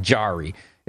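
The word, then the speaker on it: Jarry: I mispronounce it and say Jari a lot. It's Tristan Jari. It's the J sound like you would say it.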